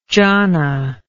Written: jhana

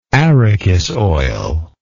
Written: arachis oil

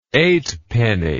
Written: eightpenny

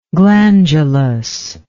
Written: glandulously

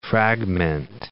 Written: fragmentize

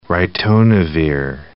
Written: ritonavir

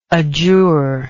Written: adjuror

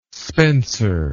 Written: spenser

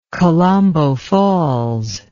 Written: kalambo falls

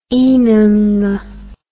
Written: inanga

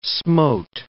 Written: smote

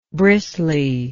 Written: bristliness

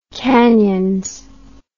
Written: canions

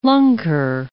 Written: lunker

